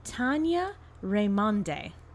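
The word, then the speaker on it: Tania Raymonde: Tanya Raimonde, I believe is how you pronounce her name.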